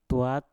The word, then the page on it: tuat: mpur